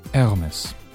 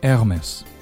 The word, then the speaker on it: Hermes: Hermes Hermes